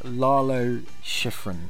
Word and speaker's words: Lalo Schifrin: but I pronounce it as Lalo Schifrin. Is that correct? – I think that's right, yes.